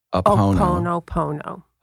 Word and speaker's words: Ho'oponopono: Ho'oponopono. – Ho'oponopono.